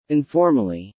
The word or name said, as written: informally